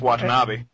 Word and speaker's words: Watanabe: It's Watanabe.